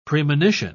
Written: premonition

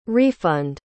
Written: refund

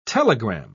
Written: telegram

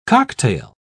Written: cocktail

